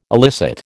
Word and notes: elicit: American English

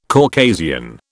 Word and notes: Caucasian: British English

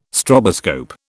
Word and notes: stroboscope: British English

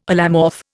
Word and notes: allomorph: Indian

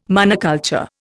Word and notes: monoculture: Indian

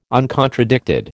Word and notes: uncontradicted: American English

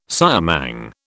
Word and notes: siamang: British English

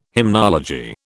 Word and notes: hymnology: British English

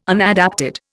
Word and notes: unadapted: Indian

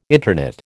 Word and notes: interknit: American English

